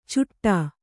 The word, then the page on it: ಚುಟ್ಟ: cuṭṭa